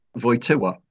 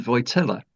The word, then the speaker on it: Wojtyła: And we'd been recommended Wałęsa and Wojtyła. But the L in both those names has a bar through it, which means that it's actually pronounced like an English W rather than an English L.